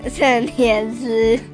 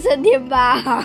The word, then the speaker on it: 升天师: 升天师。 – 对，好是升天师哦。 – 我为什么老是念成升天包？